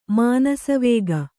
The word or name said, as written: ಮಾನಸವೇಗ